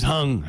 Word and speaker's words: Huang: I'm going to say Hung. If you're James Hung. – How do you spell it? – H-U-A-N-G. – Ooh, okay. I was going to make fun of you because it seemed pretty basic. But yeah, just say Hung. That's good enough.